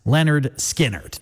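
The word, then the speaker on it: Lynyrd Skynyrd: And it was August 13th, 1973, 53 years ago today, where we finally heard pronounced Leonard Skinner.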